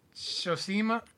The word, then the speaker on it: Tsushima: Ghost of, I know I'm going to say this right, Tsushima.